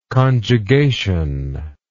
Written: conjugationally

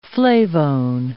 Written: flavone

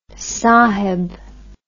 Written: saheb

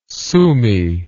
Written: Sumy

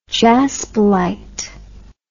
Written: jaspilite

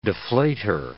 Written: deflater

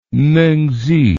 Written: mengzi

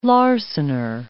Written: larcener

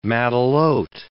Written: matelote

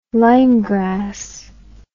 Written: lymegrass

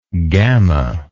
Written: gama

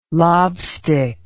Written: lobstick

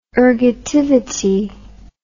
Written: ergativity

